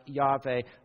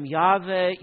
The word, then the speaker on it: Yahweh: Remember, this word, Lord, in all caps in your Bibles, is the Hebrew name for God, perhaps pronounced something like Yahweh.